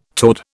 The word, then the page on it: toward: British English